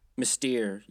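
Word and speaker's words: Mystère: This one was called Mister. I think that's how they were pronouncing it. – Mystique? – No. – Mystere? – Mystere, yeah.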